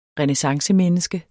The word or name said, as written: renæssancemenneske